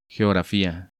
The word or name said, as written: Geofrafia